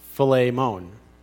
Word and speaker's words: Philemon: Now, Philemon, this is really interesting, is actually pronounced Philemon.